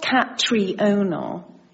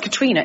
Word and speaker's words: Catriona: It's not Catriona. It may be spelt Catriona, but it's pronounced Katrina. It's Scottish, okay? – Okay.